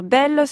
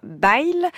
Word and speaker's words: Bayle: Pierre Bel, que l'on peut tout aussi bien prononcer comme le précise Olivier Abel, soit à la parisienne Pierre Bel, soit façon Languedoc Pierre Bayle,